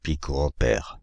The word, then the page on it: picoampère: [pi.ko.ɑ̃.pɛʁ]; /pi.ko.ɑ̃.pɛʁ/